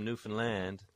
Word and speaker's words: Newfoundland: But people from Newfoundland say land, Newfoundland. Of course, some people do say Newfoundland, but they shouldn't. – Right, they're applying the unstressing on the wrong syllable.